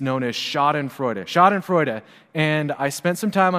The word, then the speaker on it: Schadenfreude: And then I talked to Rebecca, who apparently speaks German, and she told me that my R was a little weak, but that it was going to be okay. And so all of us together, we can just say Schadenfreude.